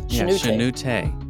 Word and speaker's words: Shenoute: And I came across your work on a very interesting person who I thought you pronounced it Shenoute, but you corrected me. – It's three syllables. Shenoute.